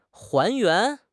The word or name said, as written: 还原